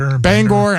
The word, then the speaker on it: Bangor: Bangor. – I don't know. I never gone to Maine. Have you ever been there? It's one of the only states. – I've been to Bangor once.